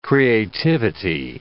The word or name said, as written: creativity